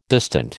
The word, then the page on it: distant: American English